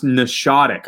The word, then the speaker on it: Nashawtuc: And I know that he's also working out of Nashwatuck Country Club as well, which is in Concord. – And it's pronounced Nashotic. I just learned.